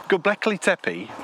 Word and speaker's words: Göbekli Tepe: Göbekli Tepe.